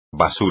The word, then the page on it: basura: espagnol